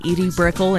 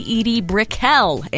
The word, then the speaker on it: Edie Brickell: I've been saying Edie Brickell in New Bohemians like it looks like, but it is apparently Edie Brickell in New Bohemians.